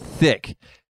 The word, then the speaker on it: thicc: In English, if someone has like curves, like the like the bone cue bone, but not necessarily like that, it's called thick. T, but it's not spelled T-H-I-C-K. It's spelled T-H-I-C-C.